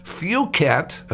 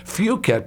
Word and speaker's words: Phuket: Phuket, and I have to be careful about this pronunciation, Phuket, P-H-U-K-E-T, Thailand.